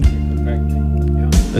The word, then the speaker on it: Kopechne: How do you pronounce her last name, Dad? Is Kopechne? Is that right? – Kopechne, yeah.